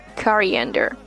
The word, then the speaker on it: coriander: And next one, also it sometimes plays as a role like an ingredient, uh, coriander, coriander. The stress sound is put on the letter A, coriander.